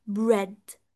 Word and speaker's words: bread: اي ان اي، اي ان اي جنب بعض، عشان كده قلتلكوا ان بريد بالرغم ان هو بيتكتب بي ار اي اي دي بس احنا بنعتبره شورت اي لانه بيتنطق بريد. بس لو جبت كلمة زي clean اللي هو نظيف، مش بقولها clean، انا بقولها clean لانه ال e a في حالته الطبيعية بتبقى e بيتنطق long e، فلما بيجي بيتنطق في bread short e دي حالة استثنائية exceptional استثنائية.